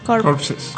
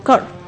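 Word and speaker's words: corps: Corpses. – Core. Mi amor. No, es una palabra francesa. Entonces, aunque se escribe corpse, se pronuncia core. – Core.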